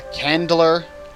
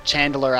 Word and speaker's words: chandler: You find that there's also a carpenter, a candler. Chandler, actually, is the proper pronunciation.